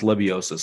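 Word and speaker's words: Lebioda: I believe it's pronounced Lebiosa. Someone can tweet me and tell me if I'm doing that wrong. There's a D in there, but I believe it's an S sound.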